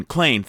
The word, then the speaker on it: Clayne: Clayton Crawford. Uh, I don't know. Is it Clayton? Clayne, Clayne, thank you.